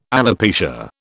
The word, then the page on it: alopecia: British English